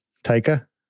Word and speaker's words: Taika: Taika? – Yeah.